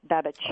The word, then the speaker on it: Babichev: It's actually Babichev. So it's almost like Babichev. – That GH is a CH, Babichev.